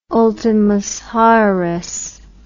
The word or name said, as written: ultimus haeres